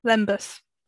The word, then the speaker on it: lembas: And I think it's, have I said it right? Lembras, yeah? – Lembas. – Lembas, thank you.